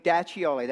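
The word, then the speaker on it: D'Acchioli: And it's Vince, see, it's D apostrophe A, capital A, C-C-H-I-O-L-I. Now, you tell me how to pronounce that. I thought it was diaccioli, daccioli. Think of ravioli. Think of ravioli, daccioli. That's how you say his name.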